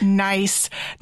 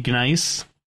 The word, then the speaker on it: gneiss: that he meant G-N-E-I-S-S, nice, not like they were nice rocks. Oh my gosh, I felt so stupid. – Well, at first, he should probably emphasize like the silent G, gnice. You could do that. That's like the city of Xi'an. X-I-A-N.